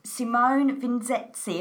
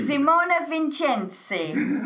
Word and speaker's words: Simone Vincenzi: Have I said that right, Simone? – You were 85% there. – Okay. 85%. Okay. What is, tell me. – Is a Simone Vincenzi. – Vincenzi. – Simone Vincenzi. I love it.